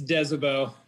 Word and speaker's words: Desobeau: It's Marcel. What was that last name? One more time. – It's Desobeau.